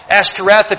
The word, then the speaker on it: Ashtoreth: It also involved the Mother Earth concept here as well, Ashtaroth. Ashtoreth, if you want to pronounce it that way, Ashtoreth, it was a physical deviance, and it was also Mother Earth concept.